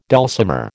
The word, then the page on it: dulcimer: American English